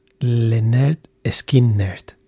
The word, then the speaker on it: Lynyrd Skynyrd: Uh, this is L Y N Y R D S K Y N Y R D, and the name of the album is pronounced Leonard Skin Nerd.